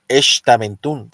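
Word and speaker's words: Xtabentún: La X, su pronunciación suena como la SH. O sea, que será Xtaventún, si no estoy mal, ¿no?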